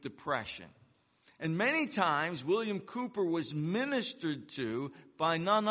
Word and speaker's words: Cowper: A hymn written by a man by the name of William Cooper, even though it's spelt C-O-W-P-E-R. I used to always pronounce it Cowper, but apparently it's not Cowper, it's Cooper.